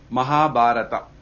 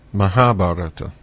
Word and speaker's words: Mahabharata: I will pronounce it for you. – Okay. – Mahabharata. – Mahabharata.